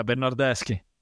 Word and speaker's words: Bernardeschi: So much so that they brought his buddy Bernardeschi. Am I saying that right? – Yeah, Bernardeschi.